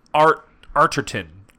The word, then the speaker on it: Arterton: It's Gemma Arterton. Arterton? – Spell it. – A-R-T-E-R-T-O-N. – Oh, Yeah, Arterton sounds good.